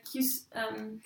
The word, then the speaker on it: cuisine: A cultural cuisine excellence. That's not how you say it. Like a cuisine. – Cuisine.